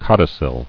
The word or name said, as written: Codicillary